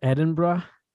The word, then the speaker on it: Edinburgh: Okay, so you you are in uh how do you say it? It's Edinburgh? – Yeah, that's it.